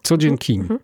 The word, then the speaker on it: Seo Jin Kim: I ona powiedziała, że w sumie najbardziej zbliżone dźwiękowo jest to do polskiego zwrotu codzień. Codziennie, tak? Codzień. – Codzień. Tak. Codzień Kim.